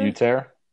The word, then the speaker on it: Yetur: Yetur.